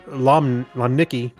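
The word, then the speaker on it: Łomnicki: Now, there's a special character in this L, so... – Just pronounce it as an L, you'll be safe. – Antoni Lomnicki, the Polish mathematician and academic, was born 143 years ago today.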